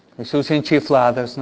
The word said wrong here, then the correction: enchiladas: ¿no? O enchiladas, perdón. Siempre lo digo mal, ¿no?